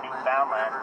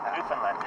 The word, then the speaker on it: Newfoundland: Mispronounce it Newfoundland, but Newfoundland.